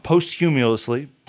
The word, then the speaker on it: posthumously: I never know how to say this word. – Posthumously. – Yeah, either way, after he died. So Tomb Fox, written in 1978. – I feel like we should know that word. We do. – Yeah, I just realized that I've been saying it wrong for a while. I said posthumously for some reason. And I realized that I've been saying it like that for way too long, and I felt really dumb.